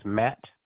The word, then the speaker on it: Matt: Sure, my first name is Matt, M-A-T-T. And last name is K-U-S, as in Sam, T-E-R.